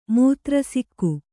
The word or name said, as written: ಮೂತ್ರಸಿಕ್ಕು